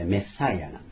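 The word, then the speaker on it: Messiah: 英語で正確にはメッサイアなんです。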